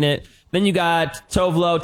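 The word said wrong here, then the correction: Tove Lo: Technically, she goes by Tuvalu, but no one pronounces it right.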